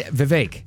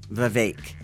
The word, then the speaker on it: Vivek: The only one I really want to see is, we don't say his name Vivek anymore. It's, how do you pronounce it? – He said Vivek. – Vivek.